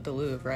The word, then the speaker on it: Louvre: That's in the Louvre, right? – Yes.